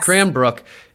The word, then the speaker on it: Cranbrook: So how do you pronounce it? What was it? Cranbrook? – Yes. – Cranbrook is right above Idaho and Montana, kind of right across the border of the U.S.